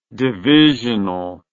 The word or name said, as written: divisionally